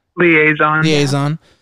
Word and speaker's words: liaison: Is that how you pronounce it? – Liaison officer.